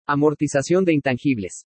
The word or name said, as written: AMORTIZACION DE INTANGIBLES